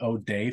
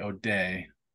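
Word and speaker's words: O'Dea: And in the days before the internet, I actually tried to find them, and it's the O'Day family. And in Ireland, I guess it's pronounced O'D, but the Americans say O'Day. O apostrophe DEA.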